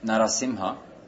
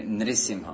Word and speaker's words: Narasimha: But it could be pronounced either way, like Nrsimha Mahadev. So Narasimha, half man, half, but it can be Nrisimha.